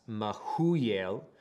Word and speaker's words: Mehujael: So, Irad, A-I-R-A-D, is pronounced Erod in the Hebrew, and then the Mehujael, we would say Mehujael, but the J is silent. So that's Mehujael.